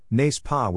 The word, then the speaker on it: n'est-ce pas: It's pronounced, phonetically, n'est-ce pas with n'est rhyming with mace. Or even face.